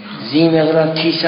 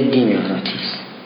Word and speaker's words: دیمقراطیس: زیمقراطیس هم بعضی ها نوشتن، اینجا دیمقراطیس نوشت، مهم نیست، چون زی جوری تلفظ میشه که مثل داره، یعنی زبان میاد سر صنایای بالایی میخوره، اونجوری تلفظ میشه، گاهی دار شنیده میشه، لذا میگه دیمقراطیس.